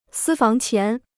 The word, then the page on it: 私房钱: sī fáng qián